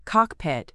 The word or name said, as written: cockpit